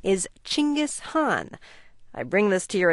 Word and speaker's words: Genghis Khan: The correct Mongolian pronunciation for the name is Genghis Khan, but especially the Hong is too difficult for most people to say. So it's sort of standardized in English as Genghis Khan.